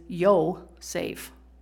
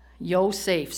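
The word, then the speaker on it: Yo-Saf (יוסף): Now, the pronunciation in the Hebrew of Joseph, which I just thought was kind of funny, is Yo-Saf.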